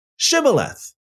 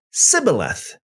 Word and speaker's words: shibboleth: If he said no, then he would say to him, then say Shibboleth. And he would say Sibboleth, for he could not pronounce it right.